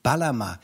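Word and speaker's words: Bağlama: Da spricht man das G ja auch nicht aus. – Baglama.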